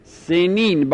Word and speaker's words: سنین: این عند قوم بنی عامر، بنی عامر سنون را باب سنون، سنون باب سنون با یا سنین، ببخشید من با واو تلفظش کردم، سنین با یا،